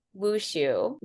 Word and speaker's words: Wushu: Because you have dance, you have, how do you pronounce it? The type of martial arts that you do? – Oh, Wushu. – Wushu.